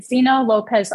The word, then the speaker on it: Xena Lopez: Instagram at Zena Lopez Art. – And that's it'll your name will be spelled in the thing, but X-E-N-A. L-O-P-E-Z, right? Spelled like it sounds? – Yeah.